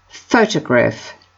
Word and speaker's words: photograph: Photograph. The stress is on the first syllable.